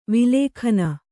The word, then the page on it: ವಲ್ಗನೆ: kannada